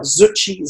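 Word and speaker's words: Zutshi: And just in case people don't know, my surname is spelled Zucci, Z-U-T-S-H-I. A lot of people think it's C. It's Z-U-T-S-H-I.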